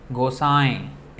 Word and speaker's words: Gosai: This is a and with a tilde on top is Gosai. It's a nasal sound, Gosai. Chaitanya Gosai. Actually, it is a Bengali way of saying Goswami.